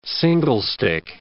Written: singlestick

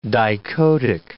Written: dichotic

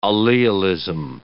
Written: allelism

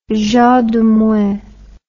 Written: jean de meung

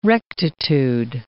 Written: rectitude

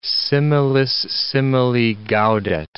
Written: similis simili gaudet

